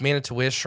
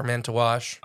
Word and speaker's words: Manitowish: Now it's Wisconsin, so it's Manitowish or Manitowash. – Okay. – It's spelled wish. But on the ice fishing pages, I've watched videos where they say wash.